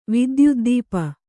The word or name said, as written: ವಿದ್ಯುದ್ದೀಪ